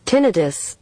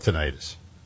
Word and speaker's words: tinnitus: Yeah, but the top American pronunciation is... – Tinnitus. – Tinnitus. – That is preferred in the medical and scientific community, also highly common in British English. So we're going to stick with tinnitus.